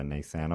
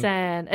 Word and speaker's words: Nissan: I like how Americans say Nissan. I've said it before and I'll say it again. – Nissan. Adidas is fun.